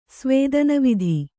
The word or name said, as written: ಸ್ವೇದನವಿಧಿ